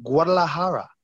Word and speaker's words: Guadalajara: I believe he's from Guadalajara.